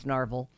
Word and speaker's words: Narvel: It's spelled like Marvel, but it's Narvel.